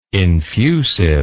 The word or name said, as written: infusive